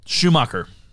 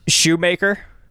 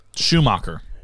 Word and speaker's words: Schumacher: This, how do you pronounce his last name? Joel? – Schumacher. – Shoemaker? – Schumacher.